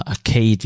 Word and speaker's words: Arcade: aber manche würden aber wahrscheinlich auch Arcade sagen, aber ich würde es jetzt einfach mal Arcade, wie die alten Arcade-Hallen aussprechen.